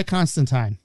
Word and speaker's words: Constantine: And that is how you pronounce the name. – He said it right. – Constantine.